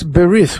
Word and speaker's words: berith: But in Hebrew, in the traditional pronunciation, it's berith, which means the covenant.